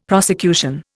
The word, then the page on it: prosecution: Indian